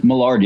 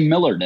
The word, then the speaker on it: Millard: Not many people say it correctly. A lot of people say Millard and I don't correct them because I just have other things to do.